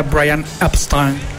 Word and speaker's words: Brian Epstein: Mientras el disco de Revolver llegaba a las tiendas, el señor Brian Epstein, como más o menos así se pronuncia Brian Epstein, en londinense, estaba pidiendo perdón en Nueva York por lo dicho de este chavo Juan Lennon, que eran más populares que Jesus Christ.